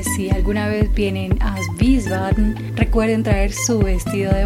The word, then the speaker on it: Wiesbaden: Y por supuesto el nombre del lugar, Wiesbaden. Todas estas palabras tienen el diptongo IE, que pronunciaremos como una I larga.